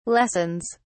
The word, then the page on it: lessons: British English